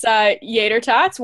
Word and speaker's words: Yater: So on Twitter, it's YaterTots, YATER_Tots. Fortunate to have a last name that rhymes with potatoes.